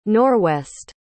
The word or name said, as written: norwest